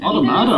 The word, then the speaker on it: Automata: Am I saying that right? – Automata, I would have said.